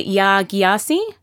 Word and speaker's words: Yaa Gyasi: Well, the next joint pick I think that we both think is the most amazing of 2016 is Homegoing by Yaa Gyasi. I'm probably saying that wrong. – I would say you're saying that correct.